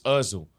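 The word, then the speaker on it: Özil: And the way the man was talking, he was like, the way he even said it, he was like, oh yeah, Ozil was on the bench for a year. It's Ozil. Like, your pronunciation is all off.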